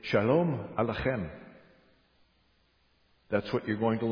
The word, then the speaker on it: Shalom Aleichem: Shalom Aleichem. Okay, now it has the letter chet, and you pronounce it the chem. Starts way back in the throat.